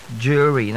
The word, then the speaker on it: jewelry: And the last word for today is the word jewelry. Jewelry, not jewelry. J-E-W-E-L-O-R-Y. Jewelry.